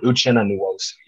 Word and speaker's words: Uchenna Nwosu: I know you said it, but is it Uchenna Nwosu? Is that how you say it? – Yes, that's actually perfect. Uchenna Nwosu, yeah.